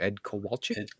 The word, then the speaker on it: Ed Kowalczyk: It's not it doesn't come off as like glam rock, but how do you pronounce his name? Ed Kowalczyk.